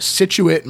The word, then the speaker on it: Scituate: And there's a small carving shop in Scituate, Massachusetts, which I did consult a Massachusetts for this. – Yes. – That is the pronunciation that they gave me.